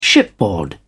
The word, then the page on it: shipboard: British English